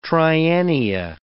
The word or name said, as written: triennia